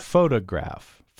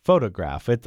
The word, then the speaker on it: photograph: Photograph. Photograph. I'm stressing the first syllable, right? – Right, A three-syllable word.